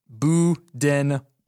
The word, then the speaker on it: Budenholzer: This says B-O-O, Budenholzer.